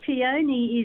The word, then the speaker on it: peony: Peony is apparently the old world pronunciation.